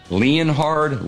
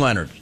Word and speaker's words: Leonhard: There are a lot of names that are not pronounced the way they are spelled, including Jim Leonhard.